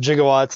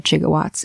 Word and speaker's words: gigawatts: Also, pronouncing it with the J sound, like gigawatts, is technically the very old method of speaking this word.